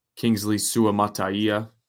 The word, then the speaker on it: Kingsley Suamataia: Kingsley Suamataia.